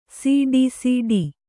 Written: ಸಿಡಿಸಿಡಿ

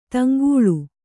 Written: ತೆಂಕಳ

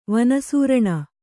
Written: ವನಸೂರಣ

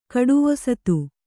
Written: ಕಡುವೊಸತು

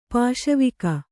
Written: ಪಾಸುವೊಕ್ಕು

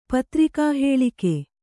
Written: ಪತ್ರಿಕಾಹೇಳಿಕೆ

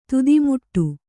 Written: ತುದಿಮುಟ್ಟ

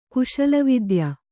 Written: ಕುಶಲವಿದ್ಯ

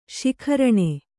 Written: ಶಿಖರಿಣಿ

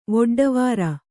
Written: ವೊಡ್ಡವಾರ

